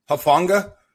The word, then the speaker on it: Hufanga: Hufanga? – Yeah.